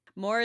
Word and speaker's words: More: More is spelled like M-O-R-E. It doesn't have two O's. – No. – Fun fact. It's like the word, please serve some more, not like the normal name, More.